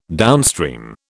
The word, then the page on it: downstream: British English